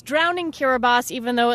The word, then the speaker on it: Kiribati: There's a country in the Pacific called Kiribati. It's spelled like Kiribati.